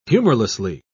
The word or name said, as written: humorlessly